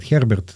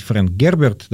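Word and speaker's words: Frank Herbert: Да, я бы назвал Фрэнка Герберта, вот Олег его по-старому называет, Херберт. – По привычке. – Да, по привычке. Вот. Ну, там классично сейчас его произносить Фрэнк Герберт, да.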